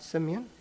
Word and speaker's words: Simeon: Is that how you pronounce it? Simeon? Yes.